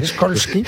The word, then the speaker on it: Skolsky: No, señor, no es SH, es SKOLSKY.